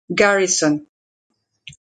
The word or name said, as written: Garrison